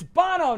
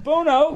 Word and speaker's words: Bono: it's like you have like a 2-hour conversation about an album and then you get one name wrong and it's like, what, it's Bono, not Bono.